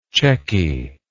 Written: chequy

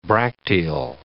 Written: bracteal

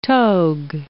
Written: togue

